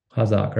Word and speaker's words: Qazaq: And that's and the way they spell it is Q A Z A Q, Kazakh, right?